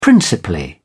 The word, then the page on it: principally: British English